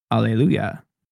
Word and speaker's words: alleluia: Alleluia. – I mean, like that. – We pronounce it with the H, hallelujah, but the original Hebrew word for this was alleluia, which with the H is silent.